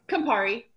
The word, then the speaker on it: Campari: Am I saying... I'm sorry, still saying that wrong. – It's Campari.